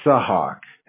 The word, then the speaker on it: Sahak: Sahak, S-A-H-A-K, but a lot of people just call me Hawk, H-A-W-K. That's fine, too. – H-A-H-A-W-K? – Exactly, Hawk. But my real name is Sahak, S-A-H-A-K.